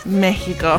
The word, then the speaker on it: Mexico: Is it pronounced Mexico or Mexico? – Mexico.